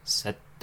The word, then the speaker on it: Szeto: How would you guys pronounce this? S-Z-E-T-O. – Probably pretty close to that. A little, just kind of a flip on the end of the S, probably. Seto. – A little bit more Z.